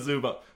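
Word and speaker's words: Zubac: It goes like Ivica Zubat, like P.S. – Ivanka Zubat. – Like, like T.S.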